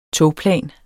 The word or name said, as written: togplan